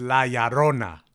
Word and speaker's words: La Llorona: Not how you say it. – Well, how do you say it? – It's La Llorona.